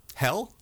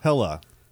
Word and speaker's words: Hela: Are you pronouncing her name wrong on purpose? – Hel? – Yes. – Hela? – Hela. – Well, it'd be is Hel in the original Norse mythology, right? – Sure.